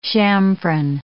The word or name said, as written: chamfron